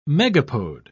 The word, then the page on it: megapode: British English